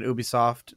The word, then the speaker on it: Ubisoft: You were working at Ubisoft.